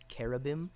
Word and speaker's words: cherubim: There is debate among scholars about how it should be pronounced, because the word is sort of borrowed from is Assyrian and is pronounced with a hard C sound. So there's a good argument to be made that it should be pronounced cherubim.